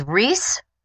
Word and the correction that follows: Reis: Rice. It's German. It's a German name for Rice. My great-grandmother, maternal grandmother's maiden name was Rice, R-E-I-S.